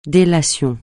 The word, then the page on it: délation: français